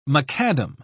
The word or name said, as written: macadam